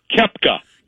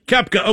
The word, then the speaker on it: Koepka: Koepka. – Koepka.